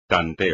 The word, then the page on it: tanteo: espagnol